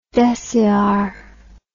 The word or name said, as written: deciare